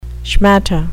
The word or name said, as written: schmatte